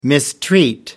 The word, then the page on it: mistreat: American English